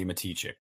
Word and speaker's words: Matichak: I pronounced her name wrong. Her name is pronounced Andy Matichak. Yes, busted. I got to get out of calling her Andy Matichak. It's Andy Matichak.